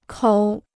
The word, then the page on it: 口: kǒu